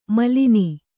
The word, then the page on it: ಮೇಲನ: mēlana